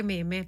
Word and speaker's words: meme: Sarebbe una scena effettivamente che può diventare un meme anche da poter mandare agli amici. – Posso dirti una cosa? Noi li continueremo a chiamare meme perché si scrive così. Sai come si pronuncia? Meme.